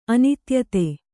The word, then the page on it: ಅನೀತಿಯುತ: anītiyuta